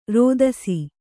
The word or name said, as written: ರೋದಿಸು